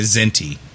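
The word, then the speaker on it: Kzinti: But you couldn't tell whether that was a Cation or a Zinti. Right, and so because the Zinti also looked cat-like, so it's spelled with a K, so it's K-Z-I-N-T-I. This is the K of silent.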